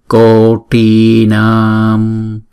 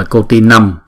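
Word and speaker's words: koṭīnāṃ: Chữ năm á, chúng ta đọc đừng đọc là cô ti năm, mà đọc là cô ti nam. Đọc chữ a, chữ na, xong rồi chúng ta mới đóng chữ na đó lại.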